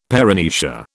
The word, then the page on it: paramnesia: British English